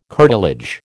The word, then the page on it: curtilage: American English